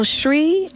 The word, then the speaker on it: Zrii: Well, Shri is spelled Z-R-I-I.